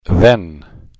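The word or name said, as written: then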